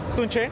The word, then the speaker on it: Tunche: And tell me about Tunchi. – Well, uh, Tunchi, uh, am I saying it right? – It's a, since the name is in Spanish, it's pronounced Tunche, but we don't mind.